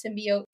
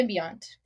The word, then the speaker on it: symbiont: I know it's pronounced this way, but I keep saying it this way. – Yeah. – That's symbiote versus symbiont for me. – Yeah. – But it's a symbiont is what the little slug is called.